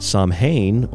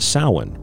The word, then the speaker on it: Samhain: We're going to get into some of the other details of Halloween, or as some might pronounce it, Samhain, or as it's I'm told properly pronounced Samhain, right here on The Secret Teachings.